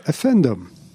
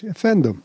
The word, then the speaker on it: efendim: Most of the time, and knowing a little Turkish, I remember noticing the peasants say, Efendim. That's how they say it, too. I've heard that millions of times because I played them so much. Efendim, which is a formal way of saying pardon.